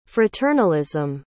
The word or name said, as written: fraternalism